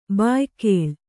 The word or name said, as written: ಭಯಾಕುಳೆ